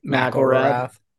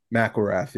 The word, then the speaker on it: McIlrath: McIlrath. – McIlrath, yeah.